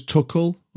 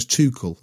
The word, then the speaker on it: Tuchel: Chris, are we having Thomas Tuchel or Thomas Tuchel? I always thought we went with. I mean, Dom's just been there, yeah. So what are we having? Tuchel? – Maybe Tuchel is a like part, the German pronunciation.